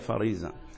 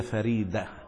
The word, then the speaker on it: فريدة: أنت عندكم اسم فريدة. ومنكم من ينطقها فريدة وفريضة، ولكن لا هي فريضة ولا هي فريزة إلا إذا كانت كلمة غير عربية، إنما إذا كانت مسماه على اسم عربي فهي فريدة.